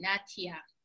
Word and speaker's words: Natia: With T, Natia.